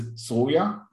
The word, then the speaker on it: Soraya: And it's in Hebrew, it is Soraya.